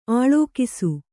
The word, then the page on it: ಅಳಕಿಸು: Kannada